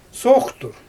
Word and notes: sooxtur: Xyzyl

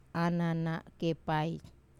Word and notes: anana kepai: Wamesa

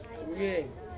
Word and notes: vieng: Ansus

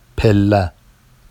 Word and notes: pella: Vafsi

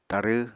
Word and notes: tarw: Apatani

